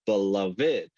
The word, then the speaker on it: beloved: But when I began to understand that there's actually three syllables in the word, it's beloved.